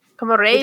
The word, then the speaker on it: komorebi: Also, I pronounced it wrong. I'm sorry. It's Komorebi. – Komorebi. – With a short E. Komorebi.